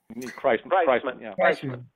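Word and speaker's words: Kriseman: There was a great deal of very good lobbying by the mayor of is it Mr. Klinsman? – Yes. – That how to pronounce his name? – Kreisman.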